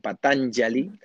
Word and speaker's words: Patanjali: El tercer día tenemos un invitado especialísimo que se llama Óscar Pujol, o pues, se pronuncia Pujol, porque es del catalán. Esa J, por cierto, es la misma pronunciación que tiene el sánscrito. La J se pronuncia como una Y, como Patanjali, ¿verdad?